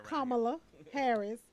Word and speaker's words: Kamala Harris: Kamala Harris.